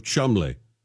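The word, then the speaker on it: Cholmondeley: She's the Marchioness of Chalmondeli. Marchioness of Chumley. Oh, Chumley's how that's pronounced. – That's even worse. – It's spelled C-H-O-L-M-O.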